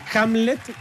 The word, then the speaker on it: Hamlet: Ahora, y Hamlet, porque no lo dije mal.